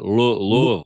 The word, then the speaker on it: Louisville: Louisville. – We're in Louisville. So we're in the bourbon capital. So there might be some bourbon. I'm not really sure. I don't partake of bourbon. But Louisville is a great little town in the heart, easy to get to, easy in, easy out.